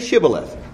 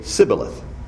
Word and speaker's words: Shibboleth: If he said no, they would say to him, say Shibboleth. And he would say Sibboleth. For they could not pronounce it right.